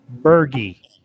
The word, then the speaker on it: Burgi: His name is Richard Booger. Wait, what? Oh, I'm sorry. I mispronounced his last name. Richard Burgi. That's his last name for real? – Well, B U R G I.